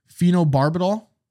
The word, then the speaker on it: phenobarbital: Phenobarbital.